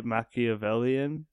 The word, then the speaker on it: Machiavellian: I actually kind of liked it because I like he's so every move that he makes is so like Machiavellian.